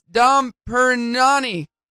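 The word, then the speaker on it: Dom Pérignon: She's like, oh, it's Dom Perignon.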